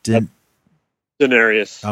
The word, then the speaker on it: Daenerys: Daenerys, how do you say her name? – Daenerys. – Okay, Daenerys. I wanted to say Denise or something.